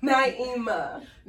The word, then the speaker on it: Naimah: Naimah.